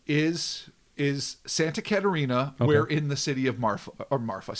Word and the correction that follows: Mafra: See, I'm saying it wrong already. – Perfect. – Mafra.